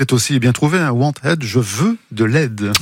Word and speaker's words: Wantaid: Et Wanted, ça s'écrit WANTAID.